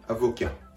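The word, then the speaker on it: avocat: 発音のポイントこれはアブオキャの V の発音です V の発音は英語と同様に唇下唇を軽く噛んで息を漏らす感じアブアブと発音するところがポイントですですがフランス語のこの息を漏らす発音というのは、英語の例えば V の発音よりもやや大げさに発音するところがポイント。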